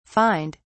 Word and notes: fined: British English

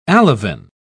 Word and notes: alevin: British English

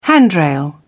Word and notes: handrail: British English

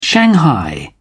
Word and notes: shanghai: British English

